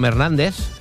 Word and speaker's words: Hernández: And the Zeta from Hernández sounds like like thunder. Feel the thunder.